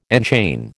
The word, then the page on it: enchain: American English